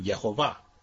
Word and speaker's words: Yehovah: At the end of a word, it is invariably pronounced Yahu. That's why I call him Yehovah, because that's the proper way to pronounce his name.